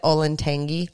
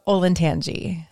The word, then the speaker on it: Olentangy: Do you know that I've always said Olentangy? – Oh, it's so wrong. Olentangy.